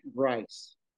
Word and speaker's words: Grice: Matt Drives? – Matt Grice. Grice. – Grice. How do you spell that? – G-R-I-C-E. – G-R-I-C-E.